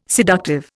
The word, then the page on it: seductive: Indian